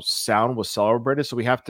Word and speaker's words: Samhain: So long ago, it was called, now I thought it was pronounced like you, Zip, Samhain, just like you see it, but it's Scottish or Celtic. It's actually pronounced Samhain. Is that right? – Samhain.